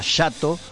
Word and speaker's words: jato: En ese momento, que se llama, en portugués se pronuncia Jato, lo tradujo como Jacto.